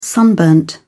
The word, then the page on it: sunburned: British English